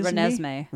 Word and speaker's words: Renesmee: Renesmee. – Renesmee. – Renesmee. – Thank you. That's the next one. Number nine is Renesmee.